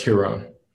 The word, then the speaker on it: Giron: Well, you pronounce the G like it's an H, so it's Giron.